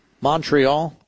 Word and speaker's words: Montreal: In Canada, Toronto, Ottawa, Montreal. – Montreal. – I don't think you pronounce the T, right? Isn't that how it goes?